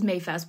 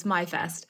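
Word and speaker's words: Maifest: I say immediately coming up, we have the biggest festival I'm working on right now is MyFest, which is often mispronounced as Mayfest, but it's MyFest,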